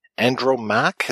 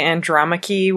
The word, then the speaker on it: Andromaque: We watch them work together on a play, Andromaque, is that how we pronounce it? – Yeah, you could also just say Andromaque, which is the actual name of the character.